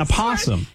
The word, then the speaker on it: opossum: The O is silent, right? – No. Don't start me with this. It's called an opossum.